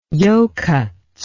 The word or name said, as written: yokuts